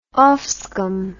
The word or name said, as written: offscum